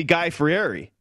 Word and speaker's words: Fieri: Yes, dude, this guy, I mean, he's basically Guy Fieri. Did I say that correctly? – Yeah, no, I think you did.